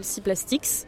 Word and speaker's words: Seaplastics: Et donc, Seaplastics, je rappelle le C, ça s'écrit comme la mer en anglais, oui. S-E-A. – Oui, c'est ça. – Et Plastics avec C-S à la fin en anglaise.